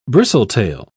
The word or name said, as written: bristletail